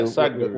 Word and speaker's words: Sadhguru: And, you know, just with the clothing, for example, there's a guy I follow, Sadhguru. Hope I'm pronouncing his name right. Like Sadhguru, yeah, Sadhguru with an H.